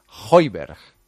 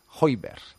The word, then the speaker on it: Højbjerg: Højbjerg. Vale. Es que se escribe H-O-J-B-J-E-R-G. Højbjerg. Así se debe decir.